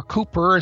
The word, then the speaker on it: Cowper: And here is a poem from William Cowper, or Cooper, as the English pronounce it, C-O-W-P-E-R, 1782.